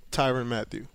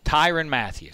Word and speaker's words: Tyrann Mathieu: How do you say it? – Tyrann Mathieu. – Tyrann Mathieu.